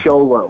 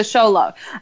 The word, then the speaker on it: Xolo: It's a little difficult because of the X, but it's pronounced Xolo Maridueña. – Xolo Maridueña. Okay. Yeah, I got the Maridueña. – As in like Han Solo? – Kind of. It's pronounced SH, though, so a little bit different. – Okay. SH. So Xolo. That is a really cool name.